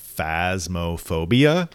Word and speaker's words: Phasmophobia: One of them that we've all played, which is Phasmophobia.